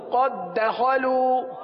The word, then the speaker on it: قد دخلوا: اذ و لام زبر لا اول میم واو پیش مو اولمو اولمو قد دخلوا.